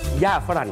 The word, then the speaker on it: หญ้าฝรั่ง: สมุนไพรชนิดนี้เรียกว่าหญ้าฝรั่งครับหญ้าฝรั่งสะกดแบบนี้ครับหอหีบยอหญิงไม้โทสระอาฝอฝารอเรือมหันกาศไม้เอกนอหนูออกเสียงว่าหญ้าฝรั่งครับ